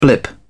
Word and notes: blip: British English

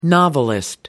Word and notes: novelist: American English

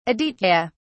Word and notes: aditya: British English

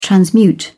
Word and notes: transmute: British English

